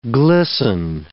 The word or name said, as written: glisten